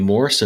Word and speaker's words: Morse: Morse, and Morse is spelled like Morse code.